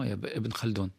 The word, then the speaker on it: Ibn Khaldoun: Non, Ibn Khaldoun.